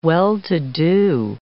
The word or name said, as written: well-to-do